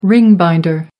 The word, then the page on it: ring-binder: American English